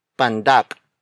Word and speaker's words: pandak: Payat is spelled as P-A-Y-A-T. It means sin. Another example is pandak. Pandak. Pandak. Pandak is spelled as P-A-N-D-A-K.